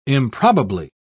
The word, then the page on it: improbably: British English